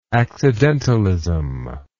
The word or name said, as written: accidentalist